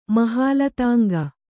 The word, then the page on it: ಮಹಾಲತಾಂಗ: kannada